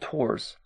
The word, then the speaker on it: Tours: Maxime had other plans for the French government to be moved from Paris to the city called Tours. – Tours. – Yeah, I don't know if that's how you pronounce it. It's how it's spelled, Tours. – Tours. – And so... – just without the S.